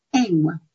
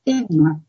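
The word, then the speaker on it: EWMA: Los gráficos Enwa, que se escriben EMWA, Enwa, que se conoce como la media móvil ponderada exponencialmente.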